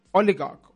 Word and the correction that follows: Odegaard: Oligarch. – No, I'm saying his name so wrong. I'm butchering his name. I'm so sorry. Odegaard.